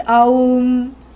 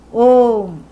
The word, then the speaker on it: Om: And even though we may say A plus U plus M, actually it is Om. A plus U always becomes O.